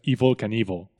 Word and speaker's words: Evel Knievel: Se escribe Evel Knievel, que eso es un juego de palabras porque, sí, al pronunciarlo es Evil Knievel.